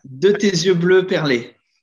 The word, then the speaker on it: De Tes Yeux Bleus Perlés: And there's another single, which I can't pronounce that. It's De Te. Okay, you want to take it from here? – Yeah, it's De Tes Yeux Bleus Perlés.